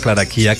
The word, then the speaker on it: Clara Chía: Y ahí se le ve pues a él con Sasha, con Milan, pero pues no se ve a Clara Chía, entonces, o a Clara Chía, como es allí en realidad la pronunciación,